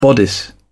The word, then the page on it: bodice: British English